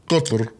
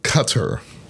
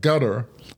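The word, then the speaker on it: Qatar: Hence, the pronunciation is cutter, as opposed to cutter. Since we English speakers don't perceive the uvular stop k or doc stops t, some started suggesting that gutter might be a more acceptable pronunciation, the easier for us to pronounce and understand.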